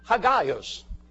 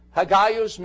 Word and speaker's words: Hagios: Hagios. Hagios means to set apart.